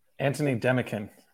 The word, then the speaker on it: Antony Demekhin: Antony Demekhin.